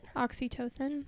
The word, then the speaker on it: oxytocin: Oxytocin. – Oxytocin.